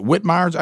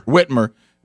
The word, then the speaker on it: Whitmer: Now, if I'm not mistaken, is it Whitmire's, how you say her name? – Whitmer.